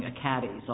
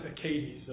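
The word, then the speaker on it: Achates: The two cases for argument this afternoon are 14-1767 and 14-1788, Acades Reference Publishing v. Apple. The first thing you're going to tell me is whether I've been mispronouncing Acades all of these weeks. – We pronounce it Acades.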